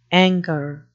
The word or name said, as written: angor